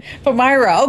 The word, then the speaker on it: Palmyra: Palmyra.